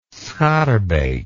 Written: schaerbeek

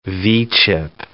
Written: V-chip

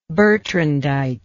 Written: bertrandite

